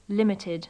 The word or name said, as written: limited